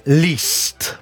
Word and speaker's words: Liszt: Sarebbe Liszt. Molti sbagliano, però io sono andato a controllare la lingua ungherese. Effettivamente Liszt. – Liszt. Molto semplicemente.